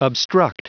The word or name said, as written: obstruct